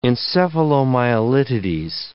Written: encephalomyelitides

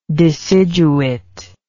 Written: deciduate

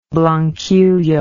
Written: blanquillo

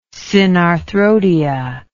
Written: synarthrodia

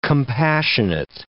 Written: compassionate

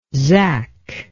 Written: zak

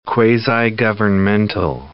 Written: quasi-governmental

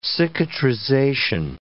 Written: cicatrization

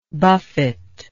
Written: buffeter

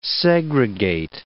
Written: segregate

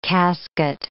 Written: casket

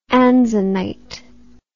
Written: anzanite

